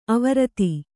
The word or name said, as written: ಆವೃತಿ